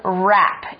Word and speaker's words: wrap: Wrap, W-R-A-P, 字首的 W 是不发音的、 mm-hmm.